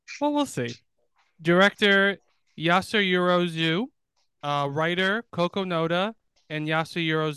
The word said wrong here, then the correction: Ozu: Starring Yasuhiro Ozu. – Okay, on the website that I have them pronounce it, they didn't have Ozu. They just said Zu. Then they were incorrect. – Because his last name ends with an O.